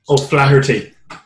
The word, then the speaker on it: O'Flaherty: Oh, Flaherty.